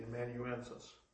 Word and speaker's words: amanuensis: See if I can remember that word. Emmanuelus. – Emmanuelus. – Okay, thank you. Which is more or less like a secretary. – Silvanus, the amanuensis. – The amanuensis, okay. I always have a hard time pronouncing that word.